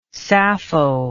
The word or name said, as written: sappho